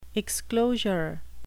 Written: exclosure

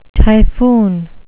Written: typhoon

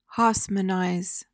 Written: haussmannize

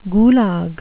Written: gulag

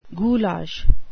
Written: goulash

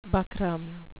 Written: buckram

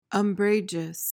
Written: umbrageous

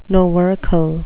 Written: novercal

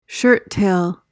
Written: shirttail

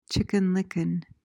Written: Chicken Licken

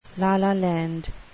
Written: la-la land

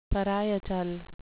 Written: parietal

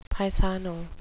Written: paisano

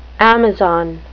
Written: amazon

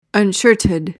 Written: unshirted

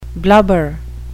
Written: blubber

